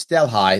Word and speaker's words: Delhi: Yes, for those of you who are not in New York, that says Delhi. It's not. It's Delhi. That's how it's pronounced in New York State.